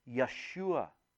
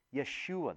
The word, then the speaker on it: Yeshua: Now, Jesus, we've talked about this before, but his name in Hebrew would be pronounced Yeshua. Yeshua. There is no J sound.